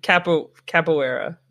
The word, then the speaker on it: capoeira: And I know I've capoeira.